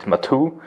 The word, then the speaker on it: Maatoo: So in German, we call it Maatoo.